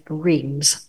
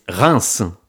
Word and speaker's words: Reims: Just 45 minutes by train from Gare de l'Est, again, lies Reims. That's how we English say it anyway. Really hard to say it properly in French. Could you please say it properly? – No, it's very easy. It's Reims. – So not easy. I'm sticking to Reims.